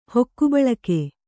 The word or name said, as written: ಹೊಕ್ಕುಬಳಕೆ